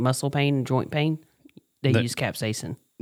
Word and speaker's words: capsaicin: it's C-A-P-S-A-I-C-I-N. Capsaicin? – Mm-hmm.